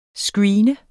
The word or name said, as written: screene